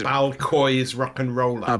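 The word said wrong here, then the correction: ball-coise: She said it's pronounced more like Bal Kosher.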